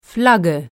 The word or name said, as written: Flagge